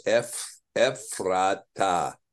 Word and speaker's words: Ephrathah: And so this is, now maybe you can say, maybe the correct pronunciation is Ephrathah, because of course, again, when we talk about the Hebrew pronunciation, we talk about the idea of the Hebrew pronunciation is this, that you see you have what's called the ultimate emphasis. The ultimate emphasis is on the last syllable of the word in every case in Hebrew, except when you have two as in a row, like derek or melek.